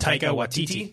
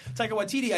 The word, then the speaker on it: Taika Waititi: Taika Waititi. – Yeah. Hey, I got it. Okay. Taika Waititi, I think he grabs this franchise and he runs with it.